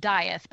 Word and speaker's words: Death: So it's D-E-A-T-H, death. And I looked it up, and it's supposedly pronounced Dyeth, but he makes a joke in the book that most people rhyme it with teeth, but I like to rhyme it with breath, something like that.